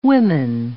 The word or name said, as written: women